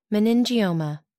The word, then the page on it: meningioma: American English